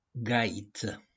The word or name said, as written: GAIŢĂ